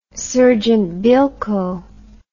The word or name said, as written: sergeant bilko